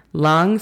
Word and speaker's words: long: Long story short. La pronunciación fonética de esta sería L-A-N-G, long. Story, de nuevo, es S-T-O-R-I. Short sería, tal y como se escucha, S-H-O-R-T, short.